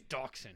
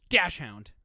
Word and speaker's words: dachshund: It's dachshund, you fucking dumbass. – You fucking idiot. I know this. – You fucking idiot. – My best friend has dachshunds, and it's not pronounced dash hound. Meg, you look like you're confused. You thought it was dash hound, didn't you?